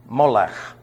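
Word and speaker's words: Melech: That's M-E-L-E-C-H. Molech, or Molech, is the way it's... I'm giving you the Hebrew pronunciation with a ch on the end.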